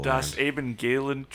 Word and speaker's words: Evangelium: And then in German, if you have a V at the start of a word, you pronounce it as an F. So Evangelium.